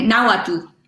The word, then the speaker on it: Nahuatl: Uh, Nahuatl.